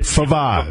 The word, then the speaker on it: Favazz: Favaz.